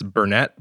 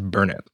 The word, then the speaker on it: Burnett: Yeah, so we tell Canadians that it's Burnett because it's the only way they watch people spell it correctly, but it's actually pronounced Burnett.